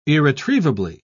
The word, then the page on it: irretrievably: British English